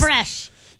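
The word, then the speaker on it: crème fraîche: Creme fraiche, did I say that correctly? – And chives. – Creme fraiche. – Yes, there you go.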